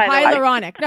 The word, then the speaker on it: hyaluronic: So, what I'd like to do for people who are listening, obviously you're a collagen expert, can you say it for me? – Hyaluronic. – It took me a long time to know how to pronounce that, Andrea. – All right, I'm getting hyaluronic. No. – There you go.